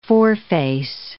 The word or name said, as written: foreface